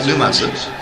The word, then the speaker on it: Numazu: Did I pronounce it right? Numazu? – Numazu.